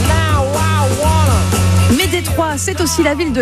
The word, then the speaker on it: Détroit: Ouais, alors déjà, c'est une ville que ça devrait se prononcer Détroit, parce que c'est une ville française, en fait, oui.